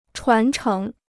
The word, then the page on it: 传承: chuán chéng